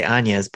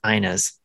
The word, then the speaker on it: Ina: I keep wanting to say Anya's, but it's not Anya's, it's Ina's. I had to look it up because it's spelled I-N-A.